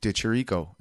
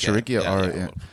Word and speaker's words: De Chirico: Alessio De Chirico. Is that how I say his name? – Chiricchio, I think. – Chiricchio, yeah.